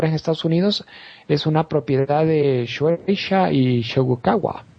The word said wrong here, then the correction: Shogakukan: Bueno, se pronuncia Shogakukan. Casi lo mismo.